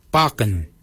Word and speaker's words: baginy: For beard, we say barken. Barken is spelled B-A-G-I-N-Y. Barken. Barken is your beard. The upper mouth, we say warrigal. Warrigal. Warrigal is spelled W-O-R-R-A-G-O-L.